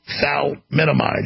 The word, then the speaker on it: thalidomide: Malformations due to the maternal ingestion of, I have pronouncing problems, it's thalidomide, yeah.